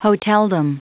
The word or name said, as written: hoteldom